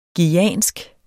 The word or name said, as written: guyansk